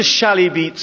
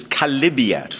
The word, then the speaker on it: chalybeate: They call it a chalybeat spring. It's actually a mispronunciation of chalybeate from the Greek, and it's a filthy thing, like a car sump down in the Pantiles.